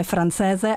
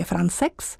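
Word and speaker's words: Françaix: Žána, jak my zde říkáme, francéze, ale byla jsem poučena mým kolegou z Lucemburska, právě Karlo Jansem, který v ní hraje, že se to vyslovuje Francex. Takže Jean Francex, kvintet číslo 2, dvě části z něj.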